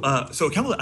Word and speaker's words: OCaml: Was it OCaml? Is that how you pronounce that? – That's right. The language is called OCaml. – Can you tell me more about that? – Yeah, so OCaml actually came out of me learning Perl from your book.